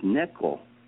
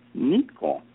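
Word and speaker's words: neko (ねこ): And in Japanese, and she was interested in Japanese culture and body systems too, cat in Japanese is neko. Neko. But the family could not pronounce it correctly, and they called her Niko.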